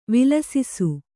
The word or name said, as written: ವಿಲಸಿಸು